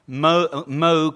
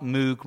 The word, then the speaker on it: Moog: Moog rhymes with rogue, not Moog rhymes with fugue, so he really cared about the pronunciation.